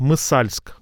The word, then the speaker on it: Масальск: То есть Мысальск, город Масальск, но будет произноситься Мысальск.